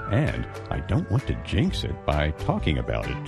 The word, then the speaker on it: jinx: Jinx. – 징크스의 정확한 발음이 Jinx. – 이렇게 하셔야 됩니다.